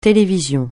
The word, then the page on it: télévision: francais